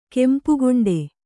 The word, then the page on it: ಕೆಂಪುಗೊಂಡೆ: kempu goṇḍe